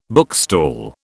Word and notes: bookstall: British English

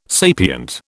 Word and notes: sapient: British English

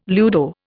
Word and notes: ludo: Indian

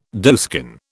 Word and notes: doeskin: British English

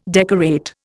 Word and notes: decorate: Indian

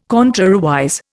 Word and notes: contrariwise: Indian

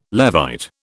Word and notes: Levite: British English

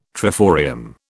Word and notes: triforium: British English

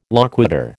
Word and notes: loquitur: American English

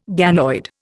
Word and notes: ganoid: Indian

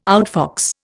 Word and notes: outfox: Indian

